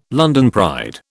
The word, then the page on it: London pride: British English